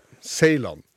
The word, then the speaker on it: Seiland: Hvis det er rett uttalelse av Seiland. – Ja da, Seiland.